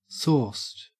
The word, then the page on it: sauced: Australian English